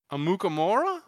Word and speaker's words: Amukamara: Amukamara? – There you go. There you go. There you go. You added like three other syllables. – Yeah. You went like Amukamara.